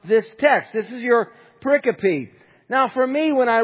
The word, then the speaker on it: pericope: So I want to introduce you to a word, and the word is pericope. Kind of a cool word, pericope.